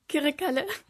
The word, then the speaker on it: Kırıkkale: Und deswegen konntest du auch Kırıkkale so richtig schön aussprechen. Könnt ihr auch mal bitte Kırıkkale sagen? – Kırıkkale. – Da hört man es schon.